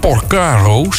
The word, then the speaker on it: Porcaro: Overigens, als je echt een drumliefhebber bent, dan weet je ook dat hier een unieke drumpartij in zit van Jeff Porcaro. Of Porcaro, zeg ik dat goed? Ja, meestal wel.